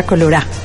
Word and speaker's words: colorá: Y bueno, eh, eh, en el fondo, en este momento está sonando la pollera colorá, así tal cual lo estoy pronunciando, la pollera colorá.